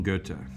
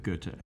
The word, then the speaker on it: Goethe: Johann Wolfgang von Goethe. But pro tip here, that O-E in his name is the old way to make the O umlaut, the O with the two dots,